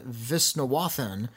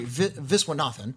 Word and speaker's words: Viswanathan: But a plucky young student reporter, played by Geraldine Viswanathan. – Oh, I like her a lot. – Yeah, from Blockers. – Yeah, she's great. – Excuse me, Viswanathan. I mispronounced her name.